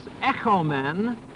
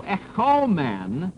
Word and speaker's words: echomen: And the fact of the matter is that those differences arise from the peculiarity of the Greek language that the verb is echomen, with a short O, is an indicative we have peace, and with a long O, echomen, it means let us have peace.